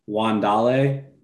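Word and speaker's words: Wan'Dale: It's actually, it's pronounced Wandale Robinson and Rondale Moore.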